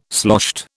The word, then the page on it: sloshed: British English